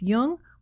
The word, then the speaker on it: Jung: You can also listen to this episode on your Amazon Echo device simply by saying Alexa, play Speaking of Jung on Apple Podcasts. Just be sure to pronounce Jung with a hard J.